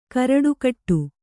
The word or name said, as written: ಗಾರುಡಗೊಡು